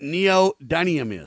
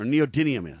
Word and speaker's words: neodymium: Let me ask you if you know what neodymium is, or neodymium is. Do you know what that is, Kurt? Neodymium? – No. – Let me spell it for you. N-E-O-D-Y-N-I-U-M. Neodymium, or neodymium, however you prefer.